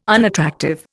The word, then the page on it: unattractive: Indian